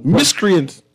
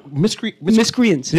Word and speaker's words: miscreants: Miscreants. That's how you pronounce the word. – But no, I won't pronounce the word. Miscreants.